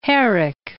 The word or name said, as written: Herrick